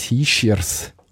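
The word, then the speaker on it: Hischier: Da gibt es Familienstreit bis Hischiers, wie man korrekterweise müsste aussprechen.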